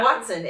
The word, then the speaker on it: Hoatzin: H-O-W-A-T-S-O-N? – Yeah. – Hoatzin? – Yeah. – Watson. It's spelled like it sounds.